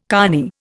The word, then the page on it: cony: Indian